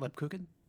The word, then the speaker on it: Lebkuchen: Is that how you pronounce it, Lebkuchen? – Uh, yeah. Okay. Kuchen.